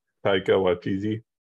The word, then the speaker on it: Taika Waititi: Takati Taika Waititi.